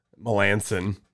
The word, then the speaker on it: Melancon: The name that I have forever been pronouncing wrong up until maybe like 2 years ago, Mark Melancon, Melanson. – Yeah. Did you call him Melancon? – Yeah, I used to call him Melancon.